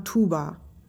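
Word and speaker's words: Tuğba: Das spricht man Tuba. – Wie? Was? Wie das Instrument?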